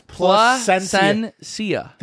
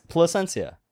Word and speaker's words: Plasencia: Palencia. – No, it's Placencia. The S is pronounced. – Palencia. – Just say Pla. – I just said it. – Say Placencia. – Placencia.